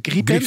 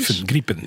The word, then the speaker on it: Gripen: Hoe spreek je dat uit? De gripen. Griepen, ja.